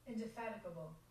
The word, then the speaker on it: indefatigable: Here's how it's pronounced. Hold on. Ready? Indefatigable? – Indefatigable.